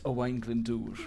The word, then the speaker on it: Owain Glyndŵr: Owain Gwyndor. – it's Owain Glyndŵr. – I'm going to give John a point because he's giving the Bristolians pronunciation of that contentious name.